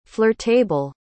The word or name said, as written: flirtable